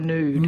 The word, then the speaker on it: nude: So I wonder how you pronounce the word, I'll spell it, N-U-D-E, whether you put a yod in there or not. N-U-D-E. Would that be a nude or a nude? – Nude. – Nude, with a yod, yes.